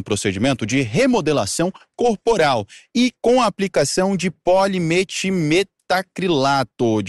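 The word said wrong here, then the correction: polimetilmetacrilato: O PMMA, vou repetir, polimetil metacrilato.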